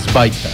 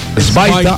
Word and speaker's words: Sweater: Realmente, como dice el cantante de la banda, tú dices Svaita. – Svaita. Ah, porque es alemán.